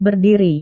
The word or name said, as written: berdiri